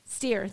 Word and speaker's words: Steyr: Where we drove it is at the Magna Steyr. Am I saying that right? Steyr. Thank you.